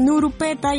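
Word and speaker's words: Null Peta: se escribe Nurpeta, pero pues bueno, en japonés tiene la pronunciación de Nurupeta, ya saben por eso de que en japonés no existe el sonido de la L, se pronuncia Aru.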